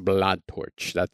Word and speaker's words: Blanctorche: I did not know for the longest time that her last name was like pronounced Blanctorche.